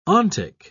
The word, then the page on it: ontic: British English